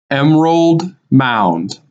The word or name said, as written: Emerald Mound